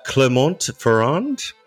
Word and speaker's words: Clermont-Ferrand: Clermont-Ferrand? – Yeah.